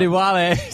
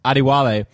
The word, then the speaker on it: Adewale: Adewale. Sorry, that's actually an easy first name to pronounce. Adewale.